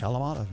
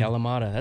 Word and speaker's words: Kalamata: I like the, I think I pronounce it right, the Kalamata olive. – Kalamata. Yeah. – Kalamata, that's a good salty olive on a salad.